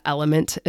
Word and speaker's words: LMNT: I'll put the link below in the show notes, or the element is how you say it. But if you look at it, it's LMNT packets.